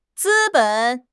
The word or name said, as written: 资本